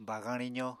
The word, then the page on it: bagañ rizo: Apatani